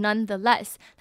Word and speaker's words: nonetheless: Nonetheless 指的是但是或者是仍然，它有点像是 however，它是一个转折的语气，就是即便如此仍然如何，会用 nonetheless。那其实这个字的发音呢就是 n-o-n-e none the less 连在一起，那大家这个发音也不需要有什么任何转折，其实就是三个字把它并在一起 nonetheless，跟 nevertheless 是一样的。那我听过有人念什么 nonetheless 或者是 neverless，那这样都是不对的，就直接把 nonetheless 还有 nevertheless 都念出来就好了。